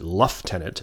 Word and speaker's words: lieutenant: Sorry, they pronounce it luff-tenant at that time.